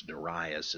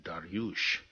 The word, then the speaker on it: Darius: Of course, Darius is the way historians render, English-speaking historians render the ancient Persian Dariush, but some folks say Darius, which kind of sounds pretty neat, too.